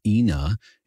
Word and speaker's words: Ynaa: They're called the Ina. It's spelled Y-N-A-A and pronounced the Ina.